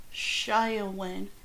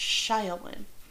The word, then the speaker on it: Samhain: In modern times, Samanha, a Celtic word pronounced Shiawin, is usually celebrated, so it's Shiawin.